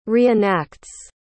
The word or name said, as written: reenacts